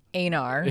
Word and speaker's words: Einar: I can't unhear the word anal when they say Einar, because it's spelled E-I-N-A-R, but with their pronunciation in Japanese, it sounds like they're saying anal.